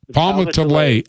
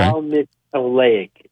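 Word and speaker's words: Palmitoleic: And how do you say that again? Palmitoic acid? – Palmitoic acid. Yeah. Okay. – Palmitoic. Yeah.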